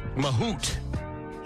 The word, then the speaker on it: Mahut: Home team, I think it's pronounced Nicolas Mahut. – Mahut. – He's French.